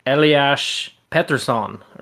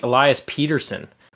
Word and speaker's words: Elias Pettersson: Like, I'm not 100%, and I'll probably butcher it, but I believe the correct pronunciation is supposed to be Eliash Pettersson or something along those lines. And yeah. he was just like, no, I kind of like Elias Pettersson, which no one calls him Pettersson.